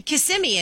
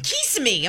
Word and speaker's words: Kissimmee: She's the one who's confused me, and I don't know how to say Kissimmee anymore. – Yeah. – I think... Did I just say it right? – You said it right. – Yeah, because she's like, Kissimmee. I'm like, that's not how you say it.